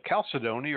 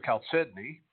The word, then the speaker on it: chalcedony: So tonight we're going to look at chalcedony or chalcedony. I've heard it pronounced both ways, and I pronounce it both ways, depending on my mood, depending on which voice in my head is predominant.